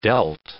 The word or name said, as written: dealt